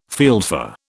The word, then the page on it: fieldfare: British English